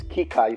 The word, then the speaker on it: Kika: My name is Kika Chukwu. Thank you so much. For those of us who will find it difficult pronouncing the name is Kika. You can just Kika for short, K-I-K-A, or Kika Chukwu, K-I-K-A-C-H-U-K-W-U.